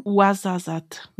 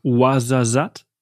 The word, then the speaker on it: Ouarzazate: Ja, ich habe ja, korrigiere mich nochmal, Ouarzazate, ich habe es vorhin falsch ausgesprochen. – Ouarzazate. – Ouarzazate.